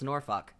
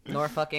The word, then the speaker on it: Norfolk: I looked up a pronunciation. It's pronounced Norfolk. So, Norfolk, England.